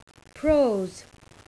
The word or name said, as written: prose